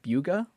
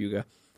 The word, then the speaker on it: Bugha: but it's like Buga, or basically spelled Bugha, mm-hmm. Buga.